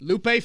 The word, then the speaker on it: Lupe: And I said Loop. I apologize if it's pronounced Lupe. I don't know, but Lupe Fiasco. – That's right.